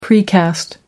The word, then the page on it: precast: American English